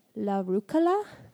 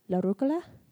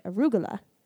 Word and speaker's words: rucola: And in northern Italy and a lot of other areas, it's actually like la rucola, like la instead of a, and then k instead of g. So it's like la rucola. – Okay. – But in southern Italy, they pronounced it arugula.